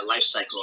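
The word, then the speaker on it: Lifecykel: It's spelled like the Danish way. It's a CYKEL, Life Cycle.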